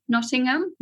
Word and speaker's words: Nottingham: We kind of don't pronounce the H. – Oh, really? – So Nottingham. – Nottingham. Is that better? – That's much better, yeah.